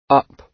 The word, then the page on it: up: British English